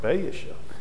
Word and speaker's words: Baasha: And so Baasha, I try to pronounce both A's, and it sounds Baasha.